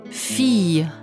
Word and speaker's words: fille: ILL wird wie J in Ja ausgesprochen. – Fille. Billet. Famille.